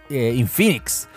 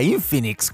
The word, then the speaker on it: Infinix: Y de hecho ya escuchaba gente, yo partí mal pronunciándolo, que yo creía que era Infinix. Y después empecé a darme cuenta que era Infinix cuando en las presentaciones empezaba a escucharlo oficialmente.